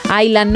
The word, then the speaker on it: island: En Island no pronuncian la S.